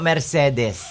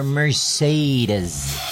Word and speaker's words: Mercedes: O yan po ang correct pronunciation po ng bayan na yan, ha? Hindi po Mercedes. Ang correct pronunciation po are Mercedes.